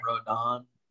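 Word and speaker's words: Rodón: What do you – did I say his name wrong? – You said Rondon. I was trying to find the end in there. – Rodon.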